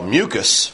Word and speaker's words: mucus: Because there's a word, M-U-C-U-S, which is pronounced mucus. And there's a word, O-U-S, which is pronounced mucus. So they are homophones, words that are exactly alike in pronunciation but differing in spelling and are different words. Mucus, M-U-C-U-S, is the noun.